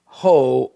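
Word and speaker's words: ho (ὁ θεός): In the Greek text, it doesn't just say theos, it says ho, or you might pronounce it o theos.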